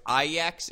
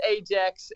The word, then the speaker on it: Ajax: So it's Ajax is how you pronounce the soccer team, because for years I've called it Ajax. Like 100%. – Yeah, and in Dutch we say A for the A. So, yes, it's Ajax in English.